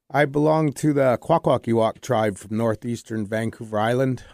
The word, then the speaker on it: Kwakwaka’wakw: Well, you always got to say it like this. Do the walk of the Kwakwaka’wakw. Say the whole thing. – Do the walk of the Kwakwaka’wakw.